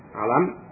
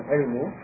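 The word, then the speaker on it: alam (عَلَم): Yang pertama alamiyah, maksudnya itu terkait dengan nama, ya, alam. Ya, jadi al-alam, ini membacanya alam ya, bukan ilmu, tapi alam, ini nama yang dimaksud.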